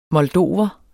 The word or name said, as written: moldover